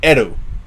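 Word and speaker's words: Eru (エル): In Japanese, he is pronounced as Eru. And that is how they pronounce the L, the English L.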